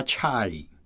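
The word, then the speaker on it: chai (чай): 而且俄文的茶的发音比英文来说更像我们现在讲的那个国语的发音。 – 你发一下俄文的茶。 – 他们的茶就叫 chai。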